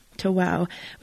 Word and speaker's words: tawâw: It's spelled T-A-W-A-W, tawâw, which is a Cree word that means welcome, there's room.